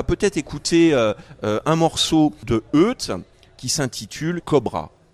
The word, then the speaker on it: Oete: On va retrouver Eut, qui s'écrit O-E-T-E, mais qu'on prononce Eut, qui est absolument formidable.